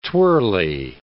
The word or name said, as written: twirly